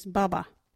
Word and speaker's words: baba: Our local Wadjuri word today is baba.